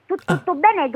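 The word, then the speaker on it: Tuttobene: Tutto bene, tutto attaccato con accento sulla E e non sulla O, se no si legge tutto bene, non mi pare.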